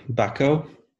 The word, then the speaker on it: Bucko: Bucko. – Oh, I was going to say, please say it properly, though. Don't say it the way we horrible Americans say it.